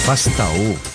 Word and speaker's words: Faustão: tem um cliente nosso que é bem conhecido lá, de, de, de televisão, Fastao. – Faustão, Faustão.